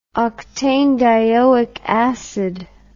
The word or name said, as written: octanedioic acid